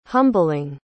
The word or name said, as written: humbling